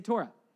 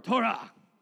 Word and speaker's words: Torah: Everybody say Torah. Say it like a good Jewish boy or girl. Torah. Well done.